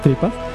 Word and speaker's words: 3Pas: Se ha dado el anuncio oficial esta semana de que la compañía productora de Derbez, que se llama Tripas, se escribe 3PAS, o sea, Tripas, ha